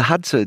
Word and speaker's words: Hadza: Nej, Morten, du udtaler det faktisk helt perfekt. Hadza, ja. The Hadza,